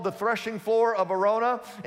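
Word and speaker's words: Araunah: Arona is how you pronounce that.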